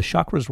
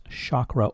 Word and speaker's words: chakra: Well, the chakras work the same way. And by the way, that word is pronounced chakra or chakra. It's your choice.